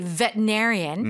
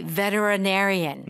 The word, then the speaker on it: veterinarian: Now, there are two pronunciations. Mm-hmm. You could say veterinarian. Mm-hmm. I've heard it many times. Mm-hmm. Or you can say veterinarian. – Mm-hmm. – Okay. So there are two acceptable pronunciations.